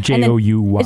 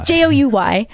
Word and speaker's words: Jouy: And then the French, particularly in this one town, Jouy, or I'm actually not 100% sure how to pronounce it. – It's Jouy. – It's Jouy.